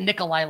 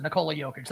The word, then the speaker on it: Nikola Jokic: I thought you said Nikolai, like Nikolai Volkov. That was a little closer. Nikola Jokic. That was okay. – Nikola. – There you go. – Nikola Jokic.